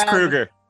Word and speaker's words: Kroeger: It's Kroeger. It's Kroeger. We left the umlaut off, when the grandparents came over from Germany, so it's Herr Kruger, yeah.